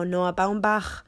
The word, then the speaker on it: Noah Baumbach: Now, Noah Baumbach. Why do I pronounce it like that? Because I can speak German.